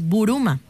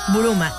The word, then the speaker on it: Buruma: Así lo pronuncian ellos los japoneses, Buruma. Buruma.